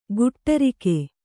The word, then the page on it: ಗುಟ್ಟರಿಕೆ: guṭṭarike; Kannada